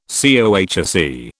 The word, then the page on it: COHSE: British English